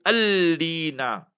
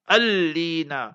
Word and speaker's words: Alleena: then the best way to pronounce it is Alina, Alina. A-L-L-E-E-N-A.